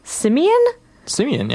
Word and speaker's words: Simeon: All right, so I'm gonna just start off my set with a single song, and it is from the new, how do you even pronounce this, Simeon? – Yeah, is that right? – That sounds good.